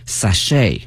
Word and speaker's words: sachet: 同时啊，这个词我们看一下，表示小的这种袋哈，古代人带的这种香囊，特别小的那种小袋子，或者呢现在女士们哈带这种小的特别小的那个兜，那么用这个词 sachet 啊 S-A-C-H-E-T。那么这个词啊它的发音哈同学们要注意，首先呢我们看一下 ch，它呢是发成 sh 音哈，不是 ch 音哈，同学们注意。另外一个呢，这个 t 是不发音的，t 是不发音的，所以呢我们要注意这个词。